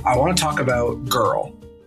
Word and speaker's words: GURL: So it is spelled G-U-R-L. because we're fun. – Girl.